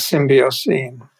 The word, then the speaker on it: Symbiocene: Am I pronouncing that right? Era. – I think he says symbiocene.